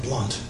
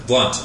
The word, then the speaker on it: Blount: It's, by the way, he pronounces his name Blount. – Blount, I'm sorry. – They all do. B-L-O-U-N-T. Mm-hmm. Great old Alabama name.